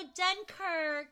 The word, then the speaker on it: Odenkirk: Did I say his name? – Oh, Odenkirk. Yeah, I love him.